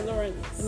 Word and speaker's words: Lawrence: Wait, how do you say the last name? – Sabrina Lawrence. – Lawrence. See, I knew that. At first I thought it was like Lawson.